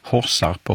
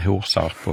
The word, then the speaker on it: Horsarp: Då kan vi börja med Horsarp och Horshult, som det säkert uttalas, och inte Horsarp och Horshult.